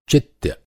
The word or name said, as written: चित्य